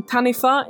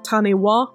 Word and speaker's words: Taniwha: And a good note as well, if you want to learn anything about the Maori language, WH is pronounced like an F in Maori, which is why I'm saying Taniwha instead of Taniwha, which I said last week, but that's how it's pronounced. That is generally how the WH sounds in Maori.